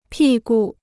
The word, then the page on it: 屁股: pì gu